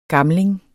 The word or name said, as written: gamling